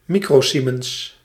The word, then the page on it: microsiemens: Nederlands; mi·cro·sie·mens